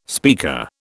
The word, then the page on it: speaker: British English